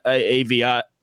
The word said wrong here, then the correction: Oviedo: Did I pronounce that right? – Oviedo. – See, I was Aviedo.